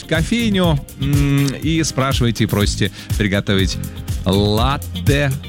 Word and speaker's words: латте: И знаете, это правильно, потому что слово итальянское, ударение там стоит на первый слог, латте. И вот, несмотря на обилие гласных, произносится твердо, без мягкости, латте.